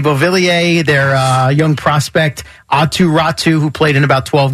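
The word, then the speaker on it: Räty: I know, because it's spelled R-A-T-Y. – Yeah, okay. – Atu Ratu.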